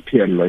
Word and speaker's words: Phehello: Am I saying it right? Phehello. Is it Phehello? How do you say it? – It's Phehello. Yes, you're right.